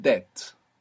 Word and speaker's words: debt: The English word debt, which is spelled DEBT, has this B from debo. It comes from there. We don't pronounce it in English, of course, but this word is from Latin.